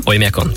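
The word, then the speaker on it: Oimiakon (Оймякон): E, to najchladnejšie miesto na svete, ja dúfam, že to dobre vyslovujem, Oimiakon. Oimiakon, tak, tak.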